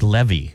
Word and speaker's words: Levy: That right there is Cash Levi. Cash Levy is the way you pronounce it.